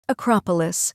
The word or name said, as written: Acropolis